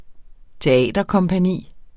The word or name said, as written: teaterkompagni